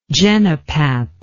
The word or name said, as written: genipap